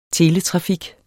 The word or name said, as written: teletrafik